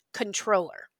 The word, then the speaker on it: comptroller: But logistically, or I should say linguistically speaking, right, the correct term is comptroller, even though it's spelled with a C-O-M-P-T, right?